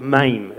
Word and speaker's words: Mem: And we're flicking back a couple of stanzas now to the one called Mame, is kind of how you pronounce it. It looks like mem. Could be a meme, but it's Mame, so it's not a meme.